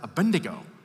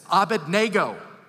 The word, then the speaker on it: Abednego: By the way, when I was a kid, I was taught to pronounce it Abednego. That's not how it's spelled. It's Abednego. You're welcome to some of you who grew up with flannel graphs and pictures in Sunday school. Abednego. No, Abednego.